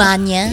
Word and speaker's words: magna: Manya? – Yung manya cum laude naman talaga yung tamang pagbigkas, hindi magna, diba?